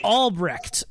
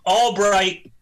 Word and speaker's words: Albrecht: Albrecht. Is it I think it's Albrecht. Albrecht. – Well, he's called Albright, but yes, it's spelled Albrecht.